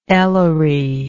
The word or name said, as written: Ellery